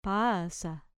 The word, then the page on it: pasa: grec